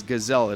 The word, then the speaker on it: Gazaille: And it's Gazelle. It is pronounced that way, and it's spelled G-A-Z-A-I-L-L-E. Help the Gazelle Family.